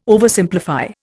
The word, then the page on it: Oversimplify: Indian